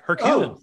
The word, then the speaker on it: Herculean: It's not like some Herculean, I'm saying that word wrong, but no, you are, like, you're saying it right. Herculean.